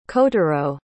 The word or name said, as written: kotaro